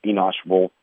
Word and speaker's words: Enosh Wolf: If you look at what happened with the big German kid, I think it's Enosh Wolf, I think that's how you pronounce it. – Yes.